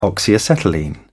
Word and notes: oxyacetylene: British English

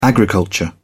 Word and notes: agriculture: British English

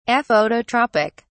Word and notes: aphototropic: British English

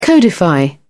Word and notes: codify: British English

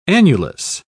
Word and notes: annulus: British English

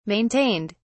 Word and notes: maintained: British English

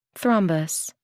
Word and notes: thrombus: American English

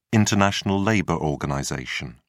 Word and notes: international labour organization: British English

